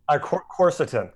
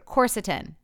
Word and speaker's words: Quercetin: I'm going to butcher the name of it, but Quercetin? Is that it? How you say it? – Quercetin. – Quercetin.